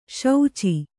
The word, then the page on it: ಶುಚಿ: kannada